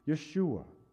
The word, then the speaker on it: Yeshua: Yeshua was the Hebrew form, pronunciation. Jesus is the Greek form, the Greek pronunciation.